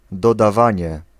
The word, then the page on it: optellen: /op.tɛ.lǝⁿ/